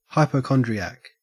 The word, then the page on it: hypochondriac: Australian English